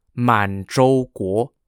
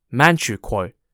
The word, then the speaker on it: Manchukuo: The Japanese had controlled the northeast of China from 1931 and established a puppet state called Manzhouguo, or pronounced in English as Manchukuo, Manchukuo spelt M-A-N-C-H-U-K-U-O.